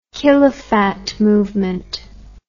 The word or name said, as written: Khilafat Movement